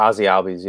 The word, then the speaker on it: Ozzy Albies: You got Ozzy Albies. Is that how you pronounce his name? – Yeah, Ozzy Albies, yeah.